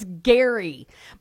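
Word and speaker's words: Gerry: His name is Gary, but it's spelled G-E-R-R-Y. I thought his name was Jerry. You can tell I don't watch The Bachelor because I don't care.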